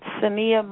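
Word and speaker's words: Saniyyah: Sania Mayo.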